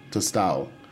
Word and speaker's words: Tostao: It's spelled T-O-S-T-A-O, Tostado.